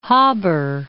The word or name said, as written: Haber